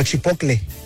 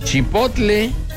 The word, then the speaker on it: chipotle: Espérate, y vamos a preguntar, ¿cómo se dice chipotle o chipocle? Vamos a ver, espérate, espérate, vamos a ver qué dice el internet. Y ahí te va. La forma correcta de decirlo es chipotle.